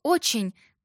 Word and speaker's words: очень: И еще слово «очень» произносится через «е», а не через «и».